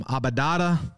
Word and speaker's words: adobada: I had some churros and I had some adobada. That's how you say it.